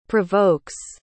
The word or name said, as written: provokes